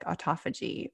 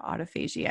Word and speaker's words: autophagy: And I kind of want to switch gears a little bit, because you have a book called Metabolic Autophagy, or Autophagy. I've heard it pronounced both ways.